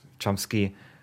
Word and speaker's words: Chomsky: Komsky, spricht man ihn aus? Ähm, Chomsky.